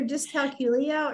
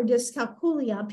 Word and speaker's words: dyscalculia: It's either dyscalculia or dyscalculia. People pronounce it differently.